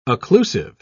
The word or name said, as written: occlusive